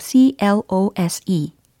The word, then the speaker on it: close: close 라고 하시는 분들이 계신 것 같아요. 근데 저는요, close 라고 발음을 할 겁니다. 이게 닫다, 접다와 같은 동사일 경우는 close, close, close 이렇게 끝소리를 내셔야 되는 거거든요. 근데 만약에 close 라고 하시면요, 어, 형용사적인 활용이 되는 겁니다.